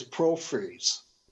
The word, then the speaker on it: porphyries: And that word is porphyries, P-O-R-P-H-Y-R-I-E-S. That was what that word was. I mispronounced, so sorry about that out there.